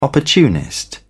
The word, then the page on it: opportunist: British English